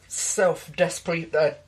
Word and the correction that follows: deprecation: Deprecation. – Thank you. I can't pronounce it.